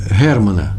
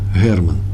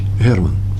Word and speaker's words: Герман: Германа. Я правильно произношу, да? Герман – украинц, украинское Г. H, Герман. Или Герман, как вам удобно.